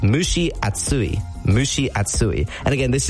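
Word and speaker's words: mushi atsui (蒸し暑い): Mushi is the first word. Think of like a cow saying moo, and then describing a lady, right? She, mu, she, mu, she.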